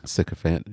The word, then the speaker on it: sycophant: How do you say this word? Sycophant? – Sycophant, yeah.